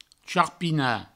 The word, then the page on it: charpinar: charpina; provençal